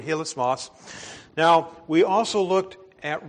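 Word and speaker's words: hilasmos (ἱλασμός): The Greek word here that is used is pronounced hilasmos.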